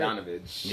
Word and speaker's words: Bogdanović: And then you got that Bogdanović. Is that how it's said? Bogdanović.